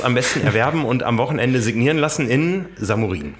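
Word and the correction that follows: Šamorín: Nee, Chamorin spricht man es, glaube ich. – Chamorin habe ich auch gelernt, als ich letztes Jahr da war zur Berichterstattung.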